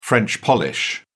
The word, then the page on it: french polish: British English